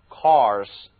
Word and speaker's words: coarse: 好了， course 我们讲了，它还有一个同音词读作 course，写法是 c-o-a-r-s-e，这是一个形容词，